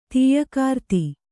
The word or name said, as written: ತೀಯಕಾರ್ತಿ